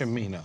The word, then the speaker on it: Cimino: If I say his name, it is Cimino, correct? – Yes, Cimino.